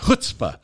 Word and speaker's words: chutzpah: Chutzpah.